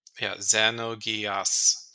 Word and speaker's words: Xenogears: So you have a pronunciation guide, and it's not Z, it's Ze. It's Xenogears.